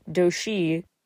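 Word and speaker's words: Dosie: Do-si, in Korean it's pronounced a bit more like Do-shi.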